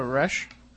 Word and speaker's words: Resch: And you write about a great case in the book, in There's Something Under the Bed, and that's the case of Tina Resch.